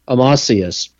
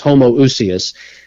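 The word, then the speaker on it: homoousios: There was an early controversy in Christianity between the Orthodox and the Arians, and they would often debate this Greek word, and it would be pronounced in the modern Greek pronunciation as Amasius, and seminary grads using an irrelevant, outdated, unusable Erasmian pronunciation will refer to that as Homoousius, but it simply means same substance.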